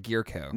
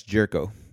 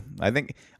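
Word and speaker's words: Jurickson: Is that how you pronounce his name? I was like, Gierko. – No, it's Jerko. – Jerko? – Jerko.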